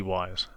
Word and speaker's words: Wyze: And Mr. Wise, or is it Mr. Wise? – Wise. W-Y-Z-E. Mr. Wise. – Could be Waze as well, right? – No. W-Y-Z-E would just be Wise.